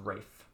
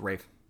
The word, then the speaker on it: Ralph: But no, it Ralph, the way it's spelled... it is pronounced Rafe. – That's so weird. – Yep. It's Rafe.